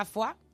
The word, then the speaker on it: foie: Además es curioso que es la palabra, o sea, se pronuncia foie con UA, pero se escribe con las tres vocales que no son ni U ni A, son es verdad. – O sea, tú escribes OIE y luego dices UA.